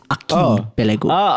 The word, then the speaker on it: archipelago: No, we pronounce our C's as ch. – But archipelago is a Malay word. And archipelago in Malay is with a K. So it's archipelago.